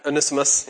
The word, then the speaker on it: Onesimus: He's asking for Omnius, or how do you say his name? – Yeah, Onesimus, yes.